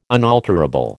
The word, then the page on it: unalterable: American English